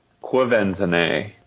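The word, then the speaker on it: Quvenzhané: It's pronounced Quivenzane, for anyone out there wondering.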